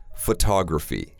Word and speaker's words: photography: Well, like a lot of my Chinese friends really like photography. – 摄影 Photography, which means you like to take photos. – 对，所以 photos 是 O 的音，但是说摄影就是刷嗓。 – Right, photography, because the stress is on that second syllable.